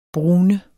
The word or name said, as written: brune